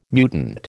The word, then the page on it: mutant: American English